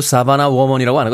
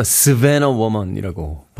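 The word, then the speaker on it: Savannah Woman: 발음도 사바나 워먼이라고 하는 거 스베너 워먼이라고 발음합니다. 노랫말 중에 한번 이야기 드렸었는데요.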